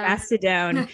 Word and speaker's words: Mastodon: Mastodon.